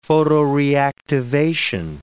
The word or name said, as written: photoreactivation